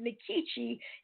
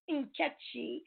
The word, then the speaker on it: Nkechi: I pronounced it Nkechi. If I were in Nigeria, it would be pronounced Nkechi.